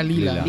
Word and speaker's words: Leila: No sé la pronunciación, pero se escribe Leila. Leila. – Sería Lila. – Lila.